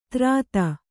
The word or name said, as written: ತ್ರಾತ